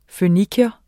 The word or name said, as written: føniker